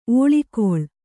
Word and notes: ಒಳ್ಗಾಲ: Kannada

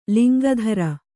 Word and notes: ಲಿಂಗಧರ: linga dhara

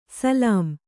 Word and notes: ಸಲಾಂ: salām

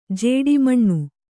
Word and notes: ಜೇಡಿಮಣ್ಣು: kannada

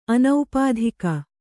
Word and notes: ಅನೌಪಾಧಿಕ: anaupādhika

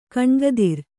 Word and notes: ಕನಕೋದರ: kaŋkōdara; Kannada

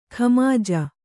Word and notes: ಖಮಾಜ: Kannada; khamāja

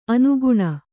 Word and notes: ಆನೀಕಿನಿ: ānīkini

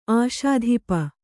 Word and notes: ಆಶಾಧಿಪ: Kannada

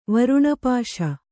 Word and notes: ವರುಣಪಾಶ: varuṇa pāśa

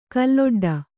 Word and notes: ಕಲ್ಲೊಡ್ಡು: kalloḍḍu

